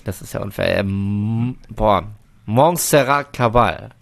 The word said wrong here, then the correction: Caballé: Nein. – Caballé. – Caballé. Bitte nochmal als Ganzes zum Reinschneiden. – Montserrat Caballé.